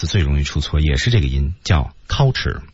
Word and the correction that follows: culture: 这个单词我刚才的读音是错的。对啊，正确的读法必须舌位在那个位置，叫 culture。